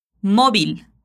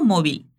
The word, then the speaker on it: móvil: Móvil. Essa palavra é uma forma mais simples de dizer telefone móvil. Perceba que o V tem som de B e o L no final é bem pronunciado.